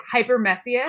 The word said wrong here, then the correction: hyperemesis: Hyper oh it's tricky. Hyperemesis? Hyperemesis. Emesis. Okay, hyperemesis.